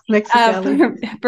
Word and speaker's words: Mexicali: It was in Mexicali, if I'm saying that correctly. – Yes, Mexicali.